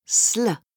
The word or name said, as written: sl